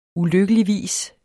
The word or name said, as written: ulykkeligvis